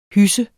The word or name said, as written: HYSSE